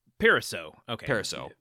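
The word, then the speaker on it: Parisot: Pariseau. You got the T is silent.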